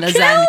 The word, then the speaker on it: lasagna: He says, as we all know, real G's move in silence like lasagna, because the G is silent in lasagna.